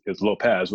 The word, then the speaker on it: Lopes: You know, and then because my name is Lopes, which we pronounce it as if it were Spanish, it's actually Portuguese.